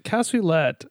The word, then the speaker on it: cassoulet: Cassoulet, right? Is that how you pronounce it? Cassoulet? – Cassoulet.